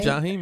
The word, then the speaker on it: Jaheim: One of my favorite albums is Ghetto Love by Jaheim, if I'm saying it right. – Jaheim, yeah.